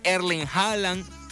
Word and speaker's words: Erling Haaland: Erling Haaland, como se pronuncia allá en Noruega,